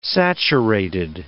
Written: saturated

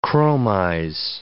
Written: chromize

